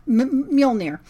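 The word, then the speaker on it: Mjölnir: Thor did something that he now is no longer worthy to wield Mjolnir. And I cannot say that right, but... – Mjolnir.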